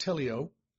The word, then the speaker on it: teleioo: And the Greek word is teleo, T-E-L-E-I-O-O. Teleo, teleu, depending on how you want to pronounce it. Normally pronounced teleo.